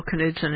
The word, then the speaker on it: Knudsen: Well, I know Bill Knudsen, and he does pronounce the K.